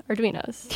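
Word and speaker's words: Arduino: Arduinos. – Oh, yeah. Arduinos.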